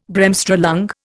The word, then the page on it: Bremsstrahlung: Indian